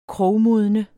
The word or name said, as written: MODNET